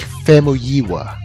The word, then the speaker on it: Famuyiwa: How do you pronounce his name, by the way? I always uh, it. It's Rick Famuyiwa. Famuyiwa. That's way better.